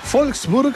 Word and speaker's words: Wolfsburg: Saludamos a Siegfried desde Wolfsburg, Alemania, ¿eh? No, es Wolfsburg, porque la W se pronuncia como V corta, ¿no? O V, ¿no? Se dice V.